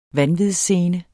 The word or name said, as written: vanvidsscene